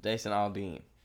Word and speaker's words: Jason Aldean: Jason Aldean.